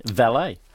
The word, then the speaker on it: valet: It's not a French word. So pronouncing the last syllable as A-Y, A, is incorrect. – Yep. – It should be valet.